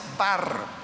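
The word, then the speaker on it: bar (בר): Porque la expresión hebrea que aparece en los originales, es una palabra que, se pronuncia bar. Y resulta que bar en arameo lo que significa es hijo.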